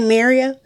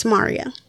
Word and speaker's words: Mario: Because some people say Mario. – Yeah, exactly. – No, it's Mario. – It's Mario. Mm-hmm. Okay. Mario Van Peebles. – Mm-hmm. – What was another famous Mario? – The singer, Mario.